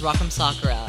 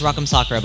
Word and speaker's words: Rock M. Sakura: So the way it's pronounced is Rock'em Sakura. It's okay. – One of my favorite things about my name, so it's Rock'em Sakura, by the way.